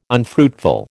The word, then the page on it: unfruitful: American English